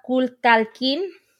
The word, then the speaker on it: Kulkalkin: And there is another story in the Yucatan known as the Kulkalkin. It's spelled K-U-L-K-A-L-K-I-N. I think I said it right? – That's how I would say it.